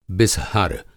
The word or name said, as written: बिसहर